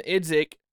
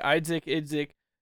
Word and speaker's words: Idzik: Well, the coach, John Idzik, I think that's how you say it, Idzik,